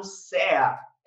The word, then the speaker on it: CEA: sobre o certificado CEA. É isso aí, Roberto, falei certo? – Isso aí, falou bonitinho.